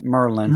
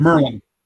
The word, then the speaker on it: Maryland: Well, first of all, learn to pronounce Maryland right. It's Merlin. – Merlin.